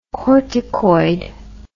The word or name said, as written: corticoid